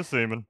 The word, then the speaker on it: Seaman: I saw it spelt, and I was like, it can't be pronounced that way. And then I saw some like behind-the-scenes stuff. I'm like, yeah, that's how it's pronounced. Peter Seaman.